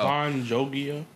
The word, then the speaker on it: Avan Jogia: Avan Jogia? – Yes.